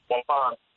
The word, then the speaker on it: Waupun: Waupun, am I saying that? Waupun? How would I say that? – Waupun.